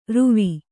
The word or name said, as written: ರುವಿ